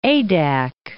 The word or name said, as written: Adak